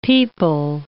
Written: pipal

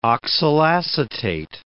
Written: oxalacetate

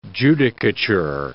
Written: judicature

